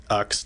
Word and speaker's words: Linux: Linux, in the same way it's pronounced Windows Operating System.